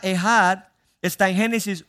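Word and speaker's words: ehad (אחד): La palabra que se traduce allí, uno, es una palabra hebrea que se pronuncia ehad.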